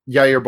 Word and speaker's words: Jair: Uh, speaking of a scary fascism, uh, Yair Bos- Bolsonaro, I believe, is that is that the correct pronunciation? – of Brazil's president? – Yes. Yeah. Jair Bolsonaro. Uh, he is just like, you know, I mean, we like the instant that he was. Oh, Jair. Sorry.